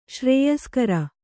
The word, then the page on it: ಶ್ರೇಯಸ್ಕರ: śrēyaskara